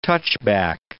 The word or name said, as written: touchback